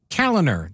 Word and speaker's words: Kaliner: My name, Pete Kaliner. That's K-A-L-I-N-E-R.